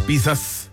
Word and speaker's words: pizza: Esas pizzas. Se dice pizza, ¿eh? Pizza o pizza con T. Ya que viene del italiano, pizza.